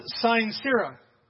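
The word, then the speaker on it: sine cera: And the two words were it's pronounced, sincera. Did you hear it? Sincera, sincera. It says, and it just simply means without wax, by the way.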